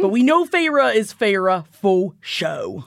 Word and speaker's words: Feyre: As the book opens, we're seeing things from the eyes of a young woman named Feyre, which thankfully, Sarah works a clever pronunciation of this name onto page 13 of the paperback, because I probably would have been calling her Feyre.